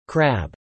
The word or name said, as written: crab